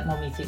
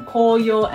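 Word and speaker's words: koyo (紅葉): We have two pronunciation, koyo and then momiji. And then koyo means red leaves in autumn and the momiji means maple leaves.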